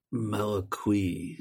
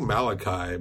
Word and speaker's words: Malaquais: But this was directed by a guy named Laurent Malaquis, last name spelled M-A-L-A-Q-U-A-I-S. Do you think I'm pronouncing that right? – Malaquis. – I think Malachi might. – Okay. – Well, it's not like spelled like the Jewish version of Malachi. It sounds like a blend between Malachi and Marquis.